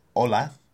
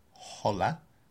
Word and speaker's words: hola: And as I'm sure everyone already knows, hola means hello. You don't pronounce the H, so it's not hola, it's hola.